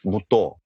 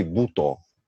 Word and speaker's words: буто: Он танцор буто или буто, или в Японии правильно сделать э, ударение как бы и туда, и туда.